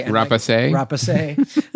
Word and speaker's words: Rapace: You know, Noomi Rapace, if I'm pronouncing that correctly. – Rapace.